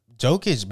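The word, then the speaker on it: Jokić: I'm telling you, Jokic.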